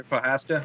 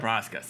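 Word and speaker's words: Procházka: But so, the answer is how do you say his last name again? – Jerry Prochazka. – Prochazka, sorry.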